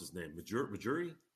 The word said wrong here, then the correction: Masai Ujiri: Oh, Masai Ujiri.